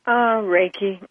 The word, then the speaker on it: Reiki: First, ladies and gentlemen, allow me to say that Athena Starr is a reverend, is renowned visionary, mystic, Reiki master. Is that Reiki? Is that pronounced properly? – Reiki.